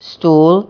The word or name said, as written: stool